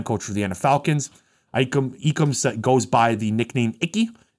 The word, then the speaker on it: Ekwonu: And his name is actually pronounced Ekwonu. Is from the Igbo language and means my strength will not be in vain.